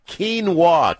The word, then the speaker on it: quinoa: Kinwan? – It's a family name. What is it? Kiwa? How do you say it? – It's Quinoa. Q-U-I-N-O-A. – Didn't I say that? – No, you said Kinwin or something.